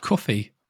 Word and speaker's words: Ko-fi: It's two words, K-O-F-I, separated with a hyphen. That's Ko-Fi. – Apparently it's pronounced coffee. – But it's got one F.